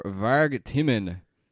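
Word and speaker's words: Vargtimmen: Or Varg Timmen, directed by... – Great pronunciation.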